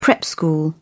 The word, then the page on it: prep school: British English